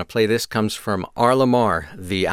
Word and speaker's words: R.LUM.R: R.LUM.R. is spelled R.LUM.R, pronounced R.LUM.R.